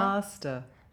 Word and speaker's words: master: Ma- master. – Master. Master. Master. It's an ah sound at the end of master.